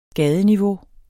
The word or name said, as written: gadeniveau